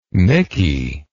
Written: mickey

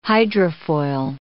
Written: hydrofoil

